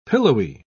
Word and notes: pillowy: British English

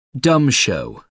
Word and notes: dumb show: American English

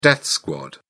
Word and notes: death squad: British English

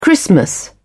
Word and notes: christmas: British English

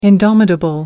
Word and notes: indomitable: American English

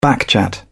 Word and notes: backchat: British English

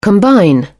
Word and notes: combine: British English

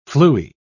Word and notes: fluey: British English

